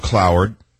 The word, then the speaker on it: Cloward: It's in my book, Trickle Up Poverty, on page 53, and I talk about Richard Andrew Cloward, rhymes with coward, and Frances Fox Piven, rhymes with driven.